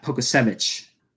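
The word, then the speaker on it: Pokuševski: Like, is not, I mean, we talked about earlier with, how do you say his name? Pokusevski. – Pokusevich. – Yeah, Pokusevich. There you go.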